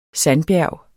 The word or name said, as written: sandbjerg